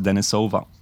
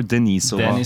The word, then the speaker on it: Denisova: Pretty much Denisova Beats, wherever you need to find me, Twitter, Instagram, Facebook, or you can find me on Spotify, just Denisova, or Denisova, Denisova. – Or Denisova, or however you want to pronounce it, every pronunciation is welcome.